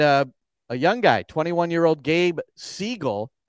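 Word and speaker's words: Segal: It's pronounced Siegel, as he told me at the hotel, like the bird, because everybody wants to go Seagal because we've all seen the Steven Seagal movies, right. So, but it's Siegel.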